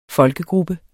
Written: folkegruppe

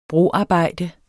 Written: broarbejde

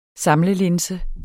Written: SAMLELINSE

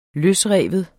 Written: løsrive

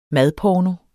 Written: madporno

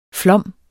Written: flom

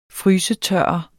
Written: frysetørre

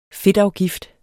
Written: fedtafgift

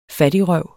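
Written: fattigrøv

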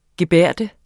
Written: gebærde

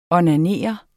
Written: onanere

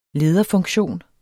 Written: lederfunktion

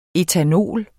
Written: ætanol

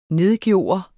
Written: nedgøre

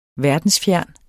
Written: verdensfjern

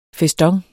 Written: feston

